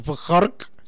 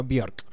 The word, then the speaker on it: Björk: Pero como Björk, no decimos Björk, sino Björk.